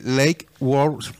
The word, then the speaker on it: Lake Worth: Oiga, saludos para Víctor Roa desde Lake Worth, Florida. ¿Sí se pronuncia? – Lake, Worth. ¿Lake? ¿Cómo se quiere? W-O-R-T-H.